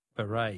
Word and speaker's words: beret: Words that end with a silent T. Beret is B-E-R-E-T.